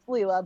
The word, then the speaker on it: Leila: Is it Leila or Leila? – It's Leila, but I.